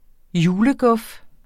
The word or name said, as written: juleguf